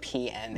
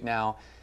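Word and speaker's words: PNAU: I always pronounce it P-N. – No, I think they're pronounced Now. And clever way of spelling Now.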